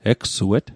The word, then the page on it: egsuet: Mi'gmaq